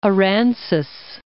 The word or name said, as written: Aransas Bay